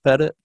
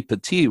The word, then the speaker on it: Petit: It's because he's French-Canadian, Petit. – Oh, Petit, okay, Petit. Okay. I was kind of anglicizing it to kind of shouldn't make assumptions about that. So Anthony Petit was the officer in charge, and he had been assigned there in the previous year.